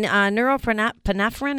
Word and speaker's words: norepinephrine: I think I'm a little low in norepinephrine. Is that how do you pronounce it? – Norepinephrine. – Norepinephrine. Okay, got it.